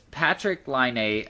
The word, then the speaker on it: Patrick Laine: And number two, which I know not a lot of people follow along with the NHL draft, but it was Patrick Laine.